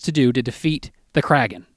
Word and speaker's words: Kraken: And really, you've got the Kraken. Am I pronouncing that correctly? – The there's a dispute over the pronunciation. – No, it's the Kraken.